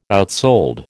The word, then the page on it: outsold: American English